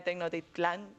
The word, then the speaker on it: Tenochtitlán: Bueno, reconstruyen la ciudad de Tenochtitlán. No, nunca sé pronunciarla bien del todo. – Sí, no, lo has hecho bien. – Lo has pronunciado perfectamente.